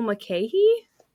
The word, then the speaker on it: Mulcahy: I was saying Russell Mulcahy. – Mulcahy. – I looked it up online, and like that's how it was pronounced on Google. So that's just how I pronounced it, but I guess it's Russell Mulcahy.